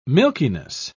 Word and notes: milkiness: British English

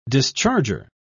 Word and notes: discharger: British English

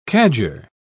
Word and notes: cadger: British English

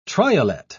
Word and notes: triolet: British English

